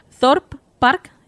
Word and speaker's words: Thorpe Park: Es el de, a ver si lo digo bien, Thorpe Park en Gran Bretaña.